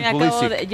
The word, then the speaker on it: Pulisic: Pues porque Pulisic, él ha pedido en entrevistas, en el anterior Mundial, recuerdo, nos tocó cubrir Estados Unidos, y él, como tiene origen polaco, es la correcta pronunciación.